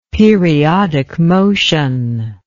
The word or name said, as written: periodic motion